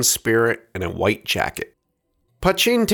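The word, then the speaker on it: poitín: While spelled P-O-I-T-I-N, with an accent on the second I, according to John Ralph, who is Irish, as well as creator of Mad March Hare, it's pronounced Putchin. Not to be confused with poutine, the Canadian French fries with gravy and cheese curds, though lovely, this is Putchin.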